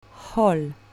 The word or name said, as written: hall